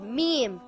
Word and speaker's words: Mim: It is a light letter and should not be pronounced heavy. Mim makes a similar sound in English to the letter M in the word moon or the word mouse.